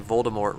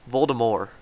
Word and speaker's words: Voldemort: Rowling made an announcement today that we've all been saying Voldemort wrong? – Stop it. – There's no, like, you don't pronounce a T on the end because it's French, so it's Voldemort.